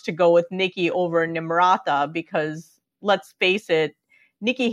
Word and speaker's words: Nimrata: Clearly painting her as, quote unquote, foreign by calling her Nimbra. And by the way, the comedy bit, I know it's not her fault, but it's actually pronounced Nimrata. So, I mean, I don't expect most people to pronounce Nikki Haley's birth name correctly.